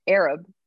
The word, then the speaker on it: Arab: Yeah, so I went to, so I ended up settling in a town called Arab, Alabama, which Arab, but it is pronounced Arab. And I will say I was going around town calling it Arab for at least a week.